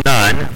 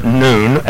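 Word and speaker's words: nun: And it looks like none, but we actually, it's pronounced noon.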